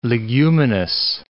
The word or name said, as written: leguminous